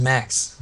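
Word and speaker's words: Maxx: How do you say Max with 2X? I don't think there's a way to articulate that in audio. – I think it's just Max. – It's just Max.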